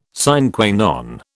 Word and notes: sine qua non: British English

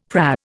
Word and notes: PRA: Indian

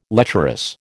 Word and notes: lecherous: American English

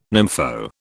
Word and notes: nympho: British English

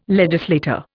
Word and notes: legislator: Indian

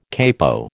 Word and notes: capo: American English

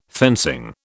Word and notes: fencing: British English

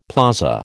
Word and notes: plaza: American English

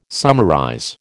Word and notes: summarize: American English

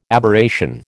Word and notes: Aberration: American English

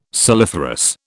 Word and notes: saliferous: British English

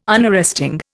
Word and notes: Unarresting: Indian

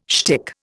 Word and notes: shtick: Indian

